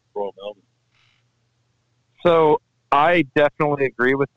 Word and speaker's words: Melbourne: Royal Melbourne.